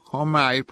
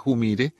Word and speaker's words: Humire: Se trata de Joseph Humaire, pronuncian algunos, pero él dice que se pronuncia Humire.